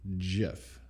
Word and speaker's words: GIF: But the creator of that file extension says it's supposed to be pronounced GIF. So instead of GIF.